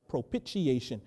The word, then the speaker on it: propitiation: 1 John, the epistle of 1 John, or 1 John, uses the word propitiation, or some people pronounce it propitiation. It's the same word.